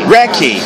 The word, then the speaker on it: Reki: Name is Ricky? – Recky. – Yes. How do you spell that? – R-E-K-I. – R-E-K-I. It's not like the massage technique, is it? – No, it could be, though.